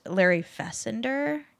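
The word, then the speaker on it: Fessenden: Ty West has a little bit of help from a producer named Larry Fessender. I think I'm saying it right. I keep wanting to be. It looks like Fassbender. So, like, that's what I think it is.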